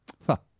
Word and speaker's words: pho: Pho. – Pho. – Pho. – But I call it pho because it's P-H-O, but apparently you pronounce it pho.